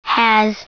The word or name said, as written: has